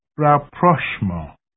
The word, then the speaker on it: rapprochement: The first is the word rapprochement. Rapprochement. And the spelling is R-A-P-P-R-O-C-H-E-M-E-N-T. – Rapprochement. Originally a French word, but now part of the English vocabulary. It retains its French pronunciation, rapprochement.